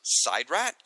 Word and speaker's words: SIDRAT: But wasn't it pronounced Sidrat on the program? Of course, TARDIS backwards should be pronounced Sidrot.